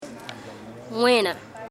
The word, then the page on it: Wera: Embera Chamí